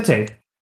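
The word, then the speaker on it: Tetê: Teta, or I think it's Tete. It's Brazilian pronunciations. Very strange. No, Tete. What a debut.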